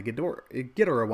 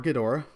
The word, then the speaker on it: Ghidorah: But since I'm talking the Ghidorah one, or Ghidorah, both pronunciations work.